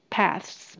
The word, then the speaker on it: paths: 这边加 s，因为有十条，那 th 舌头稍微伸出来就可以了，因为后面还要接 s 的发音。paths paths。